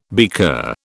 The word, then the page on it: beaker: British English